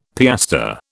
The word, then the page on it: piastre: British English